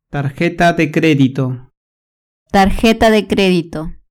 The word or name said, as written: tarjeta de credito